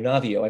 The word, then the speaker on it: Navio: I mean, this is what, you know, one of Dr. Navi, not actually it's Dr. Navio. I don't, that's a little French pronunciation.